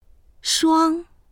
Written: shuang1

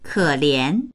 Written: ke3lian2

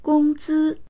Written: gong1zi1

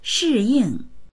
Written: shi4ying4

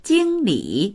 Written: jing1li3

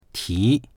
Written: ti2

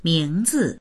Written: ming2zi

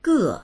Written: ge4

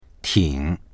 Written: ting3

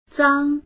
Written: zang1

